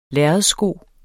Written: lærredssko

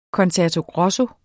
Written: concerto grosso

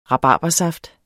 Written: rabarbersaft

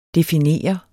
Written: definere

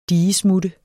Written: digesmutte

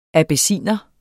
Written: abyssinier

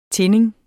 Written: tinding

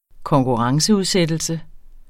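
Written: konkurrenceudsættelse

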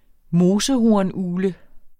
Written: mosehornugle

